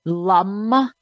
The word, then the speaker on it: lam: lum L A M, oftentimes pronounced, you know, like L U M, lum,